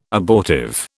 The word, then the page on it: abortive: British English